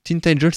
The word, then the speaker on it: Tintagel: Et Tintagel, ou Tintagel, Tintagel, c'est mieux quand même, hein, en prononciation.